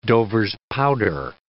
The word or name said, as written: Dover's powder